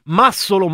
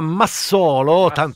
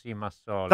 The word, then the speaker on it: Massolo: Nel frattempo mi informa la Ghidini, che arriverà dopo con Prisma al termine del giorno delle locuste, che non si dice Massolo ma Massolo. – Massolo.